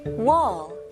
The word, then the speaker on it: wall: 발음은요, 이거는 공 할 때 ball 하고 똑같아요. 볼 아닙니다. Ball, 턱이 내려가는 느낌이에요. 그래서 다시 한번 같이 발음해 볼게요. Wall. Wall, okay? 그리고 L 발음은 혀가 윗천장으로 닿아야 되죠?